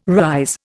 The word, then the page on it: rise: Indian